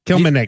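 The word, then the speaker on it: Kilimnik: After me. Kill. M. Nick. – Kilmeneck.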